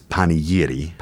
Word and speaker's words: panegyri: And so the tradition that you discovered there, let's talk a little bit about the, and I will mispronounce this, panegyri. – Okay, so there's the singular is panegyri. – Panegyri. – Panegyri, and then the plural is panegyria.